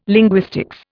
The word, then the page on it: linguistics: Indian